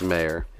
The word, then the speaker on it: Mayer: Is it Michael Meyer? Michael Mayer. It's Mayer. Is it Mayer? Yeah. Well, we're going to go Michael Mayer then. I guess it is kind of spelled like John Mayer.